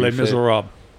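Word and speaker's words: Les Misérables: In that, Los Miserables or whatever the hell you Les Miserables. – Yeah, that's it. That's how you say it.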